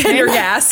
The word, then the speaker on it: Pendergast: Well, Tom Pendergrass. – Yeah. – I totally mispronounced that last Pendergast. – Name. – Pendergast.